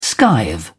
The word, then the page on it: skive: British English